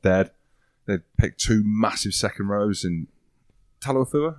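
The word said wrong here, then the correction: Taofifénua: how do you say it? – Taufi Fanua. – Taufi Fanua. There we go. Said it second time.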